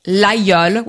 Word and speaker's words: Laguiole: In Aubrac, they make a cheese called L'Aïeul, which is the same as the name of the knife. L-A-G-U-I-O-L-E. You don't pronounce the G,